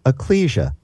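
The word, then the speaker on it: ekklesia: The word for church is pronounced in English, ecclesia.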